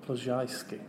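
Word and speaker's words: Ploszajski: So it's A-N-N-A-P-L-O-S-Z-A-J-S-K-I. – Pshyski.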